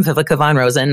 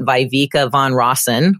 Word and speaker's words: Viveka von Rosen: So A, you know how to say my name, Vivica Von Rosen, not Vivica Von Rosen, which I get all the time.